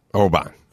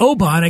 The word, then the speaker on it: Oban: O-B-A-N, the Scotch. O-B-A-N. How do you say that? – Oban. – Oban. That's what a lot of people say. It's not Oban. It's not Oban. It's Oban. So what I do, rather than say, you fucking idiot, you would say, oh, I like Oban.